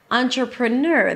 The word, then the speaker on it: entrepreneur: So first of all, the primary stress of this word is at the end. Ner, entrepreneur. That means, if you remember, the stress is higher, okay, the pitch is higher, it's a little longer, the syllable is a little longer, and it's louder. There is a secondary stress at the beginning, on.